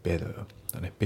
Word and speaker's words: better: あと発音ですけど、ベターの T がね、 D で濁りますね。ベターだね。